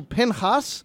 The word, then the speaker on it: Pinchas: And it's called in Hebrew Pinchas, the name of a person. Your English Bible in the book of Numbers might call him Phinehas. And so that's the two different names, the English pronunciation or the Hebrew pronunciation.